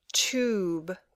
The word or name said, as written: tube